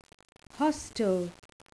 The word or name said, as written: hostel